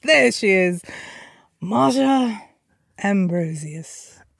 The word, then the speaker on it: Ambrosius: One more time for me. Ambrosius. Is that how you said it? – Ambrosius. – Okay, so some people do say Ambrosius, right? – Okay. – Then it's Ambrosius. I don't even know how to pronounce my own name anymore.